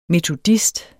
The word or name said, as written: METODIST